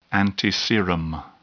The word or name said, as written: antiserum